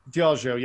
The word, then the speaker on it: Diageo: Diageo? – Diageo, yep.